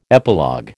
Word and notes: epilogue: American English

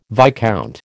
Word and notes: viscount: American English

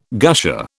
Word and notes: gusher: British English

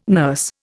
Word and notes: nurse: Indian

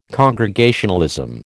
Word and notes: Congregationalism: American English

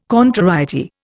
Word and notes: contrariety: Indian

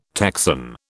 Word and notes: Texan: British English

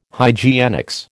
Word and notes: hygienics: American English

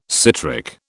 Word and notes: citric: British English

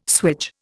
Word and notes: swage: Indian

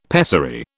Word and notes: pessary: British English

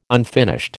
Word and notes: unfinished: American English

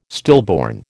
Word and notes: stillborn: American English

